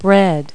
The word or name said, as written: bread